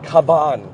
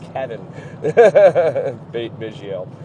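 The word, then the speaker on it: Cavan Biggio: Unlike how Michael Kay pronounced it during the Toronto Blue Jays end of the game yesterday on Yes, it's not Caban, it's Kevin Biggio.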